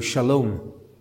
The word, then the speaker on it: Shalom: And then Salom, you'll recognize if we pronounce it in the Hebrew, Shalom.